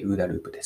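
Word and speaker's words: OODAループ: いきなりなんですけれども、ウーダループ、皆さんお聞きになったことはあるでしょうかでウーダループというのは、え 4 つの英語の頭文字からなっていて、 OODA と書いてウーダループです。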